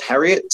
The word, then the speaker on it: Harriott: So the easiest way is going to be, my name is Charles Harriott. Harriott is spelled H-A-R-R-I-O-T-T.